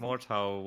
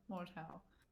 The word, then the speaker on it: Mordhau: Mordhau? Is it how you pronounce it? – I think Mordhau would be, yeah. – Yeah, Mordhau.